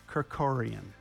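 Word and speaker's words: Kerkorian: Kirkorian, that's how you say it.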